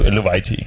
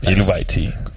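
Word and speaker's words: Eluveitie: Øh, men jeg tror, vi starter med at høre noget fra Tule og Eluviti. – Eluviti. – Eluviti. Nu ved jeg, hvordan man udtaler det. Det udtaler altså Eluviti. Hvor forsangeren sagde det. – Eluviti.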